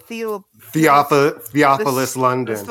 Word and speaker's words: Theophilus: And This Salopius, how you pronounce it? – Theophilus London.